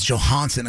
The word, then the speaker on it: Johansson: I believe it's Johansson, according to Mr. Skin. Yes, Johansson. That's why I say Scarjo. That way we don't say it wrong.